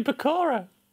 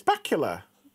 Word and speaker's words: Bakula: The way you say Bakura. – It's Bakula. Scott Bakula.